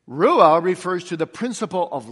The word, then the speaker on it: ruah: Now, breath is a Hebrew word, ruah. Ruah. You could spell it R-U-A-H.